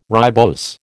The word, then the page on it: ribose: American English